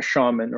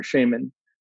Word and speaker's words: shaman: And that's when I need you to go and speak to a shaman or a shaman, however people want to pronounce it. Canadians and Americans, we say it different ways.